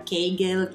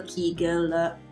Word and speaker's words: Kegel: le famose Kegel e gli esercizi di Kegel, Kegel, Kegel, a seconda della pronuncia italiana, tedesca o inglese comunque ci riferiamo sempre alla stessa cosa.